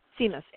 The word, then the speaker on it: Simas: go on Amazon and, you know, type in, you know, Anne Simas, I'm going to say it wrong. – Simas, S-I-M-A-S, and you can pre-order the book.